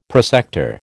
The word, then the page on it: prosector: American English